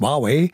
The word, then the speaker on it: Huawei: In der neuen Staffel geht es um den chinesischen Tech-Giganten Huawei, wie er eigentlich wirklich ausgesprochen ist, Huawei.